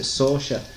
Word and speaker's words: Saoirse: Saoirse Ronan. If I say Suarez or something like that, then people might know her a little bit better. But yeah, apparently you pronounce it Saoirse.